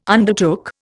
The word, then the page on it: undertook: Indian